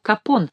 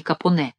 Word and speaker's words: Капоне: Он дает интервью журналистам, в коих указывает, что надлежит произносить его фамилию Капон, а не Капоне и не Капони, ибо «е» на конце его фамилии — «е» мертвое.